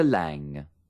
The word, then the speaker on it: Lange: Por ejemplo, he aquí tres formas diferentes de pronunciar la G. – Kim Basinger, Richard Gere, Jessica Lange.